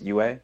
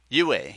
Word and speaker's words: Yue: I was gonna say her name is Yue. – Yue, yes.